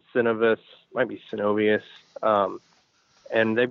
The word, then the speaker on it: Synovus: The last piece of the puzzle for Bass Pro Cabela's is hinging on a deal right now with Synovus. I think that's how you pronounce it.